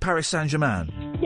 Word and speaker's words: Paris Saint-Germain: Is that how you say it? Paris Saint-Germain? – Yeah, or PSG.